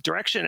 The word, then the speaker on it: Direxion: Just know that even though it's pronounced Direction, it's spelled D-I-R-E-X-I-O-N. So you might want to say Direction, but that's not how they pronounce it.